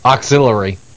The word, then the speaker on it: auxiliary: Auxiliary. – Yeah, you're still kind of getting the X there, but it works. That works. At least you're not throwing the extra syllable in there.